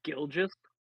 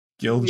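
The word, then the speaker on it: Gilgeous: It's like Gilgis. – Gilgis. – Yeah, there you go.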